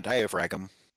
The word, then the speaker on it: diaphragm: Got to get that extra G in there. – Diaphragm. There's no silent G's. Whoever heard of a silent G? Diaphragm. No, it's diaphragm. – I like it. I like it. – If they want us to call a diaphragm, then take out the G.